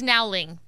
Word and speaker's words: Nowling: And I said your name totally wrong, Chad. It's Nowling. – That is correct. – There we go. Like it's spelt.